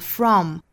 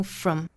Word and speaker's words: from: 有一个虚词我们要注意，就是 from, 不要读成了 from, 应该读成 from. 元音变成了音，并且这个词的元音的长度更短，语速更快，语调更低。